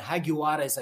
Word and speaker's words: Jaguares: the Jaguars 15, the Jaguares, I should pronounce it correctly, which is a former Super Rugby side.